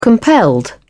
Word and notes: compelled: British English

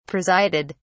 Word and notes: presided: British English

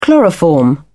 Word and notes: chloroform: British English

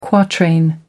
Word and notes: quatrain: American English

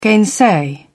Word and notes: gainsay: British English

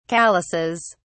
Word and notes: calluses: British English

